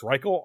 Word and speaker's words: Reichle: I believe it's Reichle, R E I C H L E.